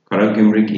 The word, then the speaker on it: Karagümrük: Karagümrük.